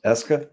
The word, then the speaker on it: Eska: Is that how it's pronounced? Eska? Yeah.